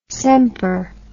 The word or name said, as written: semper